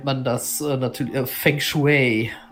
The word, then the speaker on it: Feng Shui: Aber Natürlich spricht man es Feng Shui.